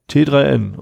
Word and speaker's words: t3n: Äh, wie spricht man dieses Magazin eigentlich aus? – T3N? – T3N, okay. Äh, vom Mai 2017, also gerade mal so zehn Monate alt.